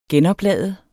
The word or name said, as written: genoplade